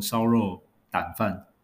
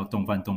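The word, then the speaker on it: 丼: 那没想到，上网查了一下，才发现原来这个字念作" 胆"，或者是破音字念" 井"，所以理论上应该叫做烧肉胆饭，或者是烧肉井饭。好，但是 anyway，可能是日文的关系，所以我们就是将错就错，日文那个字啊会念作洞，所以我们台湾人也就跟着叫洞饭洞饭洞饭，对。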